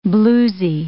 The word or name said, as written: bluesy